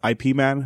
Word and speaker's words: Ip Man: It's Ip Man, I'm pretty sure. – I read it as Ip Man too. Are we correct? – You're correct.